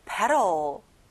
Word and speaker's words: petal: 가운데 T 발음은 안 나죠. petal.